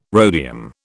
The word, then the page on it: rhodium: British English